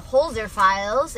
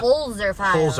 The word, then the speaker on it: Holzer: So for those of you who haven't seen it, it's called The Holzer Files.